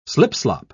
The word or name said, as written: slipslop